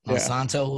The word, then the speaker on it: Monsanto: Oh yeah, and then what'll happen is like fucking Monsanto. Did I say that right? Monsanto, yeah.